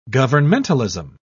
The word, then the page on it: governmentalism: British English